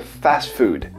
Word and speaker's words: fast food: A pronúncia do inglês fica fast food. Tem esse i no final que a gente fala em português, que é o food. Né? Esse i você corta fora se você quiser falar em inglês.